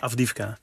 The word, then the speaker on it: Avdiivka: Avdiivka.